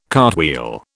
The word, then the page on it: cartwheel: British English